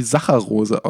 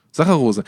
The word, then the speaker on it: Saccharose: Ich weiß genau nicht, das tut mir wirklich leid, aber ich weiß nicht, wie Saccharose ausgesprochen wird. Ich glaube so. – Das ist schon richtig. – Saccharose.